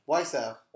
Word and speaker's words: Wiseau: Wiseau.